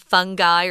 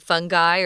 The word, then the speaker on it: fungi: Uh, I think you pronounced it fungi or fungi, didn't you, Tom? – Uh, yeah, my dictionary gave that pronunciation, so I went with that pronunciation, although I think fungi is actually more common.